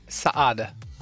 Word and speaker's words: Saade: Hur uttalar man ditt efternamn? Saad.